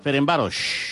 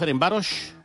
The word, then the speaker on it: Ferencváros: De fet, ahir a la ràdio, ahir a la tarda vaig escoltar nombroses ocasions el rival del Barça, Ferencváros. A més, amb una SH final molt pronunciada. Juga el Ferencváros. Com si fos una pista d'esquí.